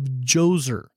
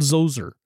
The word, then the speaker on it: Djoser: This is a man by the name, or a pharaoh by the name of Djoser, and it begins with a D. It's funny, silent D, J-O-S-E-R, sometimes called Djoser.